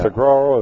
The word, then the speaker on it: saguaro: Saguaro, is that how it's pronounced? – Uh-huh.